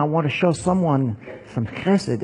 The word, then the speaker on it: chesed: Sometimes you'll see this if you have a transliterated like Bible software, and you see it's spelled H-E-S-E-D or C-H-E-S-E-D, because the H in Hebrew in this word is guttural, so it's like chesed.